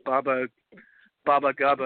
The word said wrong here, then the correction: Baba Yaga: or whatever. – Baba Yaga. Yeah.